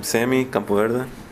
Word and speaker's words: Sammy Campoverde: Sammy Campoverde. – Could you spell that, please? – S-A-M-M-Y.